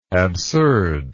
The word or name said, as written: absurdness